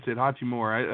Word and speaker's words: Hachimura: is that Hachimura? Hachimura? – Yeah, that's it. Hachimura.